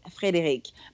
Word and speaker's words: Frédérique: English speaking people can't say it without butchering it. So I'm just like, please don't try. so I'm like, don't, because they're like, oh, Frederick, and I'm like, well, no, no, it's Frédérique. It's much softer than that.